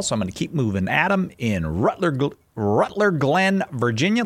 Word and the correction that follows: Ruther Glen: Ruth, that's what I meant. Just pronounced it. Sorry. Adam in Ruther Glen, Virginia, listening on the Catholic Answers app.